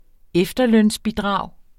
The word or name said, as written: efterlønsbidrag